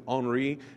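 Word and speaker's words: Henri Nouwen: I say Henry Nouwen. I think you probably pronounce it Henri because he was a French guy.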